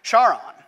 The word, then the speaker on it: Charon: Right, I pronounce it Charon. And those of you with a classical education, or those of you that are in fact Greek, will roll your eyes and say, oh, that's not how you pronounce that.